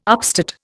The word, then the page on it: upstate: Indian